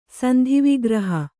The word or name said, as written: ಸಂಧಿವಿಗ್ರಹ